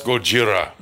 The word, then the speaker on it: Gojira: So it's pronounced Gojira.